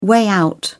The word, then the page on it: way out: British English